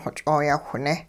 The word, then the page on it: xoch'ooyawhneh: hupa